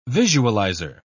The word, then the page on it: visualizer: British English